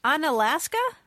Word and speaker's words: Onalaska: This is calling from Onalaska. – From where? – Onalaska, Wisconsin. – Oh, Onalaska? – Onalaska. – How do you spell that? – It's like Alaska with on in front of it.